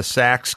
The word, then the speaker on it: Sachs: but I was around the Sox car and the Apple car a lot. And just, you know, when Matt showed it to me. – The Sox cars, the Sax cars, we pronounce it with S-A-C-H-S.